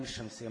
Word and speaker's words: الشمس: اللام، زي ما كنا بناخد برضو في ابتدائي يقول لك تكتب ولا تنطق، وعشان برضو لو المسألة اتلخبطت معك، اللام الشمسية مثالها إيه؟ الشمس. تمام؟ الشمس بحكمها إيه؟ وجوب الإدغام، لأنها ما تنطقتش.